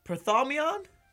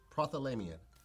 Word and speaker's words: Prothalamion: Prothalamion.